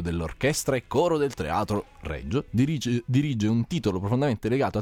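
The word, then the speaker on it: Regio: Ma c'è anche il teatro, ma non Lescaut, teatro Reggio o Reggio? – Reggio. – Reggio, grazie.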